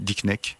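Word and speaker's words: Dikkenek: Et par exemple, un film euh, fétiche que j'aime bien, c'est, je ne sais pas si le titre je le dis bien parce que c'est belge, c'est euh, Dick, Dick Neck. – C'est ça, Oui, c'est c'est celui-là, ouais. C'est tout à fait ça.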